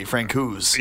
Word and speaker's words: Francouz: I understood his name was Pavel Francouz, but I'm hearing other sportscasters say Francouz. – All right. – I like the hard C, Francouz. – Yeah, okay. So we'll go with Francouz.